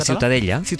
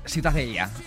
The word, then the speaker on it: Ciutadella: La Ciutadella. – Ciutadella. Bueno, pues Ciudadela también en castellano. – Ciudadela, sí. Bueno, pues eso, ha quedado clarísimo. ¿Para qué se meten? – ¿Tú cómo lo pronuncias? – Ciutadella.